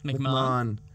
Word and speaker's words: McMahon: McMahon.